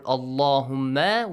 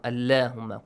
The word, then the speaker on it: Allahumma: Because of this, you pronounce the word Allahumma with a light sound. Allahumma.